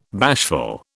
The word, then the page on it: bashful: British English